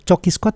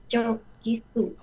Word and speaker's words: Cokisku: Yang benar Cokisku atau Kokisku ini ya? – Itu singkatan C, coklat, Cokisku, coklat isi kurma. – Oke, berarti bacanya Cokisku ya.